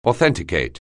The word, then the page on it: authenticate: anglais